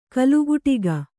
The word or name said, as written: ಕೋಲುಕುಟುಕ